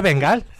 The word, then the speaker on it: Bengal: Se dice Bengal? – How do you say it in How do you say it in English? – Yeah, it's Bengal. Like a Bengal.